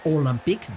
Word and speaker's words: Olympique: Olympique de Marsella, Olympique, de acuerdo a la pronunciación francesa, y Paris Saint-Germain le llaman Le Classique, un término que le ha llegado pronto.